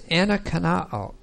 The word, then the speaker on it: anakaino: So the first verb is anakanao. A-N-A-K-A-I-N-O. And then this is a long O here.